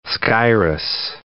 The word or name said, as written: Skyros